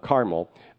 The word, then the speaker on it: Carmel: That's how you pronounce it in the Bible, like Carmel corn. Carmel.